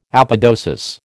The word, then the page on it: apodosis: American English